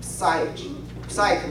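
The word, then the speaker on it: psych (ψυχή): 这个词它的发音呢，往往称为 psych 呢，意思跟我们今天的那个心理学的那个词根的 psychology 前面很像，代表的是人里边的内心的。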